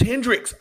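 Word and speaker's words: Hendrick's: Did I say that right? Yeah, I'm looking right at it. That's Hendrix. I know to pour that in a glass and drink it.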